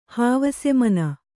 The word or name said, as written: ಹಾವಸೆಮನ